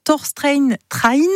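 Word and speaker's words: Torstein Træen: Torstein Train, vous l'avez bien prononcé effectivement.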